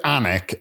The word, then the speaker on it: Alnwick: So, this is, so we went to Alnick, and I'll spell it because that's how we pronounce it in Newcastle. It's Alnwick. – Okay.